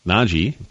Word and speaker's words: Nnaji: He's got a teammate that's showing up on Kentucky's recruiting radar on his AAU team, Zeke Nagy. That's how that's pronounced, N-N-A-J-I.